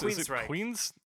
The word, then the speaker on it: Queensryche: Queensryche. – Queensryche. Telling me I've got to be aware. I think it's time to stop. – Queensryche.